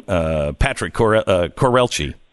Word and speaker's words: Patrick Courrielche: Welcome to the program, Patrick Courrielche.